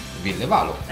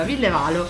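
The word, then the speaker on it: Ville Valo: Villevalo? – Chi? – Com'è che si pronuncia il nome del cantante? – Villevalo. – Giusto, allora, Villevalo.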